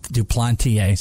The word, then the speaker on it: Duplantier: I'm interviewing John Duplantier, right? That's how we say his name, right? – Duplantier. – See, I interviewed him, and I still can't even say it right.